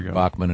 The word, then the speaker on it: Bachman: Yeah, Randy, he pronounces it Bachman, but I guess they're Bachman in the United States, is a terrific guy and a great player.